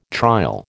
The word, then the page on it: trial: American English